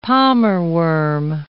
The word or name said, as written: palmerworm